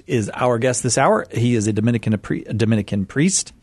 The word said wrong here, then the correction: Dominican: I added a syllable to Dominican. – Priest, yes. – Dominican, a priest, and a staff apologist here at Catholic Answers.